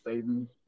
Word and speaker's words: seitan: Something I love is called seitan. I think it's actually pronounced Satan, but I just say seitan.